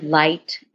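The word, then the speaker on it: Leight: I really love the, they're called Howard Light Matrix. It's spelled L-E-I-G-H-T, L-E-I-G-H-T Matrix.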